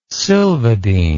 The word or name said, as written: silvadine